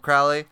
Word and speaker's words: Crowley: It's called Crowley because he's holy, and then he's like, those who want to treat him foully call him Crowley.